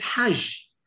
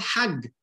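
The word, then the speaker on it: Hajj (حج): That's Hag in Arabic is Hajj. But in Egyptian Arabic, they don't pronounce the J, they pronounce the G. So in Egyptian Arabic, it's called Hag.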